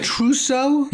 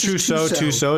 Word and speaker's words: Tussaud: Isn't that Trousseau? – Yeah. Right? But so it's Tussaud.